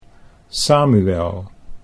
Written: Samuel

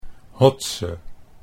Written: Hotze

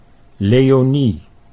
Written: Leonie

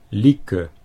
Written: Lieke

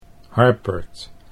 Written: Harpert

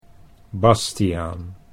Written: Bastiaan

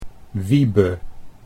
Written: Wiebe

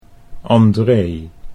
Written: Andree

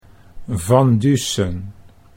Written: Van Dusen

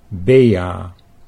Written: Bea

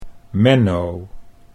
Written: Menno

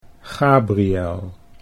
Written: Gabriel

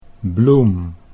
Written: Bloem

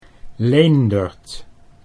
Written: Leendert

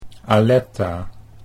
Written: Aletta

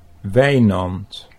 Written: Wijnand

